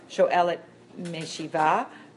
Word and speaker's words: Shoelet Meshiva: She subsequently served as a program's Shoelet Meshiva. Is that how you pronounce it? – You got it.